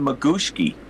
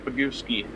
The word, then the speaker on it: Bagooski: Well, it sounds like they're saying Magooski, and it says Bagooki. Like, it's pretty. As far as I know, it's Bagooski.